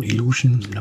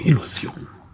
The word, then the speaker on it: Illusion: Vielleicht auch englisch ausgesprochen, Illusion, aber deutscher Verlag, daher würde ich sagen Illusion.